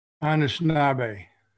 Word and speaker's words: Anishinaabe: My wife, I've learned, her family's from the Ishpeming area, and her ancestry, I think, is Anishinaabe. Am I saying that correctly? – Anishinaabe. – Anishinaabe. – Yeah, it's Anishinaabe. Yeah, Anishinaabe.